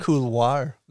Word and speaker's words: couloir: Yeah, it's a couloir for sure. Like right. Pipeline at Snowbird when you get off the tram. That thing's... – Couloir. Oh, say it like a Frenchman. – Couloir.